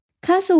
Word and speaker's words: castle: 但是这里面要加一个 t，t 也是一个不速之客，所以是 s t l e 发成了 so，castle 就是 c a s t l e，castle，sand castle。